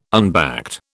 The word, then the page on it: unbacked: British English